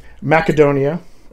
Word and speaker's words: Macedonia: Macedonia, and yes, I say it with a hard K because that's how they say it. Everybody here says Macedonia.